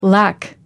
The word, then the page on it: lakh: American English